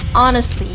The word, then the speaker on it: honestly: Itu salah kak, seharusnya tidak pakai H. Jadi kita pronounce-nya honestly. – Okay, honestly. – Oh, berarti the H one is the silence. – Silence. – Yeah. H is silent.